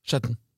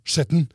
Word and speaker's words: Skjetten: Så hvis, hvordan det dere uttaler Skjetten? – Skjetten. – Ja, Skjetten. Så hvis Skjetten basketball bestemmer seg for at nå skal vi ha et elitelag, ja, ja.